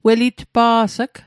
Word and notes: welitpa's'g: Mi'gmaq

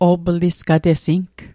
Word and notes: o'plisqatesing: Mi'gmaq

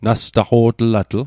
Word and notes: nastaqo'tlatl: Mi'gmaq

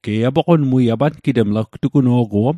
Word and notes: gtuguno'guom: Mi'gmaq

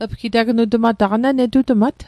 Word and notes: netutmat: Mi'gmaq

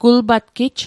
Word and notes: gulpatgij: kul'patkij

